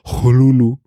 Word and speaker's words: Cthulhu: También dejó algunas notas diciendo que más bien debía pronunciarse eh, la primera sílaba como una J aspirada, algo así como Jolulu.